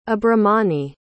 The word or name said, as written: abhramani